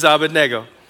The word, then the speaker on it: Abednego: is his name was not Abednego. It's Abednego, if you look at it in the text. So that's the only thing I care about the book of Daniel, you remember. His name is Abednego.